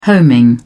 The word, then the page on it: homing: British English